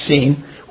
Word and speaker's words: Sin: And they took their journey from Elam, and the congregation of the children of Israel came into the wilderness of Sin, that's pronounced Sin, which is between Elam and Sinai.